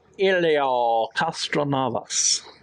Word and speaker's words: Castroneves: Helio Castroneves.